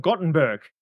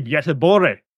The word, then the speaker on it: Göteborg: Everybody from an English-speaking society would just say, oh, it's the University of Gothenburg. No, it's the University of Göteborg.